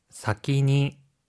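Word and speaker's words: 사키니 (先に): 사키니. – 사키니라고 하는 표현이에요. 앞선이라고 하는 한자는요, 뜻으로 읽을 때 사키라고 읽고요.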